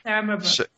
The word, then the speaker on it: Sara Mabrouk: Yeah, Sara Mabrouk.